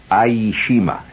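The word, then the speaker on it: Ie Shima: Aishima, it's spelled I-E, Aishima, where the camp recreational director had us all engaged in the fun activity of making napalm.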